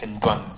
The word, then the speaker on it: Ntwambe: Do you pronounce the M? – Yeah, you pronounce actually every letter and it is Ntwambe.